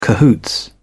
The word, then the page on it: cahoots: British English